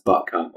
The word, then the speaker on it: Butcombe: I've got a beer from Bristol, by a brewery called Butcombe that I did think were in Devon, and it's better pronounced Butcombe, obviously.